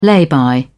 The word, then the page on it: lay-by: British English